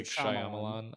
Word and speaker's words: Shyamalan: it's like Shyamalan. – Shyamalan,